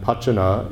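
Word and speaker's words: pacana: When, again, going back to Manjushri, if you look at Manjushri's mantra, Om Arapatsana Di, you look at the Sanskrit, Arapatsana, it comes from Ara and Pachana, Pachana, actually, if you want to pronounce it the Sanskrit way. Ara means a wheel, referring to the wheel of samsara, and Pachana is the one that will ripen,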